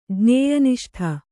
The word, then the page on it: ಜ್ಞೇಯನಿಷ್ಠ: jñēya niṣṭha